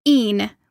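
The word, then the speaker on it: e'en: but evening has been truncated to een, which is spelled e apostrophe e n.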